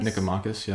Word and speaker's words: Nicomachus: Nicomachus, yeah, you said it right.